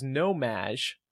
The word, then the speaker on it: No-Maj: So the word is nomaj. It's spelled N-O-M-A-J, but pronounced Madge, like Madonna's nickname.